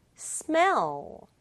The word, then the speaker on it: smell: L 두 개예요. 그러면 오오 두번 해요? 아니에요. 똑같은 소리 글자 두개올 때는 오한 번만 발음하면 된다. 다 붙이면 smell, smell. 혹은 친구들이 스멜 이런 말을 만약에 우리말로 쓰여진 걸 봤다면 두 박자로 발음할 수도 있어요. 아니에요. 한 박자로 smell, smell. 이렇게 발음해 주세요.